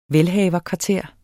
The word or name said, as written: velhaverkvarter